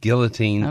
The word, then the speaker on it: guillotine: It is, in fact, guillotine, okay. – Preferably. And it was anglicized very early in the 18th century, guillotine, guillotine, guillotine. All the American and English authorities had it pronounced that way. In the early 20th century, this sort of pseudo-French pronunciation, guillotine, started being popularized. But even today, the pronunciation experts prefer guillotine.